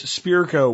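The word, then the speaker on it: Spirko: It is Spearco, which is my name, all capital letters.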